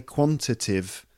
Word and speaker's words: quantitative: Actually, it's four syllables. It should be quantitative data. All right? So every time you hear me say quantitative, it should be quantitative.